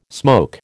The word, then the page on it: smoke: American English